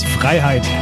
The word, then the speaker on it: Freiheit: The beer is Freeheit. The brewery is Wayfinder. – You butchered it. It's Freiheit.